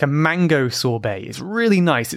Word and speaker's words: sorbet: I think this year I had one and it was a sorbet. Sorbet, I guess it's a French word. It looks like sorbet, but the T is silent, like many French words, like ballet, buffet.